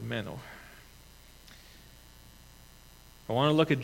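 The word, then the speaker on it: meno (μένω): And that Greek word is meno. It's not M-E-N-N-O, as in Menno Simons or Mennonites, but it's M-E-N-O with a line on top of the O. Menno. It's said that it's pronounced exactly the same way,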